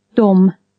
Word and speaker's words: de: Dom. – They. Spelt D-E. – Och. – And. Spelt O-C-H. – Dom. – Them, spelt D-E-M.